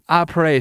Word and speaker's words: Après: Is that how you say it? – Après? – Like the French, about? – Like after, A-P-R-E-S. – Oh, sure. – I assume, I do not know.